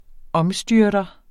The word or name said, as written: omstyrter